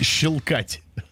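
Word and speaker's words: щёлкать: Как правильно говорить? Щелкать или щелкать? – Щелкать.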